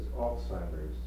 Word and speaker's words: Alzheimer's: Where does Alzheimer's, is that how you pronounce it? – Yeah.